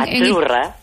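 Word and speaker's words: Azzurra: Sí, como pizza. La cita no es la cita como decimos en catalán tan sonora, no. – Ah, es achurra.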